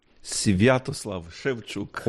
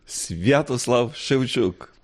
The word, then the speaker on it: Sviatoslav Shevchuk: Nos recibe literalmente en su casa su beatitud Stanislav Shevchuk. ¿Lo he dicho bien? – Sviatoslav Shevchuk. – Oh, no he dado ni una. ¿Cómo es? – Sviatoslav Shevchuk.